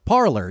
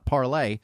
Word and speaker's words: Parler: But over the weekend, a lot of people have been quitting Twitter to go to Parler. Yes, it's pronounced Parler, according to Wikipedia, not Parlay.